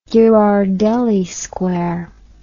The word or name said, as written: Ghirardelli Square